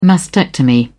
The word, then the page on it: mastectomy: British English